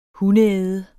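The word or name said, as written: hundeæde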